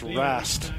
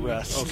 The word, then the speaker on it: Wrest: It's Rast, not Rest. – Okay. – So. – W-R-E-S-T is Rast.